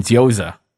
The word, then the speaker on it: gyoza: And gyoza. Okay, that's how you pronounce that.